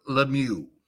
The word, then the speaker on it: Lemieux: Listen, you can call me anything you want, but they knew me as Bob Lemieux.